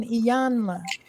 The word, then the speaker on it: Iyanla: Yeah, so she had a talk with somebody pronounced Iyanla, right? Van Zandt? – Iyanla. – Don't forget the N, Iyanla.